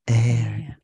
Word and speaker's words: Aire: It's spelled A-I-R-E. – Now, yeah, it's pronounced like air. – Somewhere I read said that it's just short for billionaire. – Okay. Oh, I see that. So it's pronounced air, like billionaire.